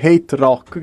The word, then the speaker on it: HTRK: Io mi vergogno profondamente, vi ringrazio ancora una volta per stasera e adesso ci andiamo ad ascoltare una chicchetta degli Hate Rock, che si scrive ovviamente HTRK, ovvero una cosa che è un po' come siamo noi, no?